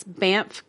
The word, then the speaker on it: Banff: Revenants of the Rockies, Banff, Canada. I think Banff is how you say that. It's B-A-N-F-F. – B-A-N-A-N-A-S.